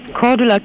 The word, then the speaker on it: Cordula: Cordula Kine.